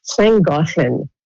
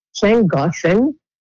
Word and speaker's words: Llangollen: Ça s'appelle, excusez-moi à tous les Gallois qui m'écoutent pour la prononciation, Llangollen. Llangollen, c'est un petit village au nord du Pays de Galles.